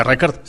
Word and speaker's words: Rickard: ¿Se pronuncia Rickard? – Sí.